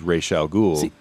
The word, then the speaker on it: Ra's al Ghul: Well, I get my pronunciation from the animated series. It was Ra's al Ghul on Batman the Animated Series.